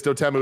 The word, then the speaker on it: Dotemu: But, you know The one thing is Dotemu is pronounced dot emu, like the large bird.